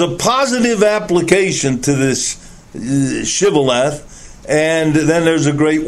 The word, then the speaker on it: Shibboleth: And if he said no, they would say to him, "Say now Shibboleth." But he said Sibboleth, for they could not pronounce it correctly, and they seized him and slew him at the fords of Jordan.